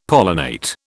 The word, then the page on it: pollinate: British English